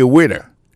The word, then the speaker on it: waiter: I am a waiter. Ça veut dire moun son garçon, m'a servi nan restaurant. Alors moi, yo prononce le waiter. Waiter, ça veut dire accent tombé sous premier syllabe là. Waiter, W-A-I-T-E-R.